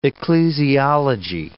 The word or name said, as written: ecclesiology